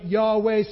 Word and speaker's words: Yahweh: It is a reference to, we pronounce it Yahweh. Some people might even understand kind of the Some people might even use the word Jehovah, though that's not actually accurate.